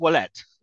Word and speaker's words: Ouellet: I think it's Ouellet is how you'd pronounce it. Ouellet. Like Ouellet, OU. Okay. So, yeah, like the French pronunciation would be Ouellet.